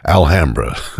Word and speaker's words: Alhambra: By the way, that's the local pronunciation. I know elsewhere it's Alhambra, but here it's Alhambra.